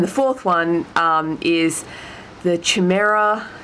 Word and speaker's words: chimera: Chimera. – Chimera. Yeah, cool. You can tell I don't. – That's how it's normally pronounced in English. I don't know how it's pronounced in Greek.